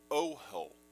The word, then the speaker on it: ohel: It is pronounced in Hebrew ohel.